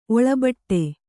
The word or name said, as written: ಒಳಬಟ್ಟೆ